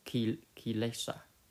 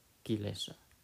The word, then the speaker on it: kilesa: That is also another key important point, to understand that this defilement, which is in Pali word called kilesa, I think it's spelled K-E-L-I-S-A, kilesa.